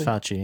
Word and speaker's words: Fauci: Yes, Fauci, yes.